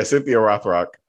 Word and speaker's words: Cynthia Rothrock: Michelle Yeoh and Cynthia Rothrock. Am I saying that right? – Yeah. – If that's your name. – Yeah. Yeah. Right. Yeah. Cynthia Rothrock.